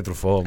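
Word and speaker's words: Truffaut: ¿Te gusta el cine trufar? – No, de trufó.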